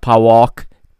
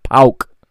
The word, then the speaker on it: PAOK: Okay, it's not PAOK, PAOK. Okay, Portuguese people are not always very good at pronouncing club names from difficult languages. It is pronounced PAOK.